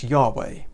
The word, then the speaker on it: Yahweh: You might notice that as you read it, that actually that is a special name of God, which we would normally pronounce Yahweh.